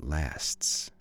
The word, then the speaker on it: lasts: Did you pronounce the final S there? Yeah, enjoy the moment while it lasts.